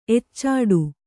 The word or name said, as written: ಎಚ್ಚಾಡು